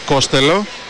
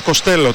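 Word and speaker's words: Costello: Πώς προφέρει το όνομά σου, λέει Ματ Κοστέλο. Τότε λέει γιατί σε φωνάζουμε έτσι, γιατί λέει κάποτε σε έναν δημοσιογράφο είχα πει Κόστελο γιατί του φάνηκε πιο εύκολο. Δηλαδή ο ίδιος ο παίκτης μας μπέρδεψε.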